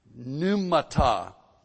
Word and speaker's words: pneumata: Now, the word in verse 19, spirits, pneumata, P-N-E-U-M-A-T-A. Pneumata, P is silent, pneumata.